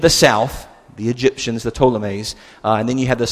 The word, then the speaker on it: Ptolemies: And then there's this continuing conflict between the Ptolemies, that P is silent from my understanding, and when you think Ptolemies, think Egypt, okay?